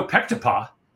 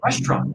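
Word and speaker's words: restaurant (ресторан): And I said, "How how do you pronounce it?" And he goes, "Restaurant." French word, restaurant, is a, is a you know Latin based word, so they pronounce the restaurant. But to us, it looks like Pectopah because Pectopah because it has all of those English uh, letters in it Oh, how interesting.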